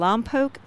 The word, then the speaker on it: Lompoc: The home to a medium security prison in Lompoc, and that's how you pronounce it, not Lompoc.